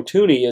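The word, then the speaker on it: Thuney: And part of the reason it's catching up with him a little bit is Chiefs offensive tackle Joe Tooney is out. Tooney, however you want to pronounce it. I say Tooney.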